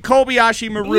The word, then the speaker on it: Kobayashi Maru: Henry to read, and I got to read this bullshit Clemente fucking Clock Maru. – Clock Maru? – It's the fucking Kobayashi Maru. – Yes! – You couldn't have told me that before. – Say it right.